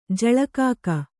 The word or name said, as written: ಜಲಕಾಕ